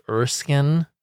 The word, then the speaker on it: Erskine: And I know that I say all of this as someone whose last name is clearly spelled Erskine, but for no reason at all is pronounced Erskine.